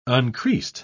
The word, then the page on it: uncreased: British English